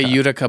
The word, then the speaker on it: Utica: I say Utica, but Michigan has a Utica, and that's how we pronounce it there.